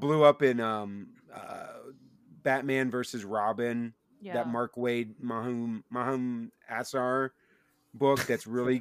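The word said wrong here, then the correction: Mahmud Asrar: Mumad Asrar? – Yeah, there you go.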